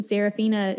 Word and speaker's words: Serafina: The Serafina is spelled with an F, like in Frank.